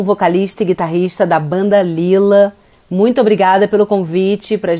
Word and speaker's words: Leela: Por isso veio Lila e se escreve L-E-E-L-A.